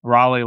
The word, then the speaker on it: Raleigh: Raleigh, like the, I was told it's pronounced like the city in North Carolina, but holes out for eagle on 18, 143 yards, 9 iron.